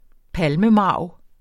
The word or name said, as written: palmemarv